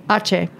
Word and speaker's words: Aceh: Aceh.